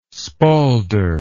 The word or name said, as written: spaulder